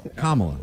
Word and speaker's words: Kamala: Can we get a pronunciation on her name, please? – Kamala. – I thought it was Kamala. – Loser. – Kamala.